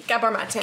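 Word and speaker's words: Gabor Maté: Gabor Mate.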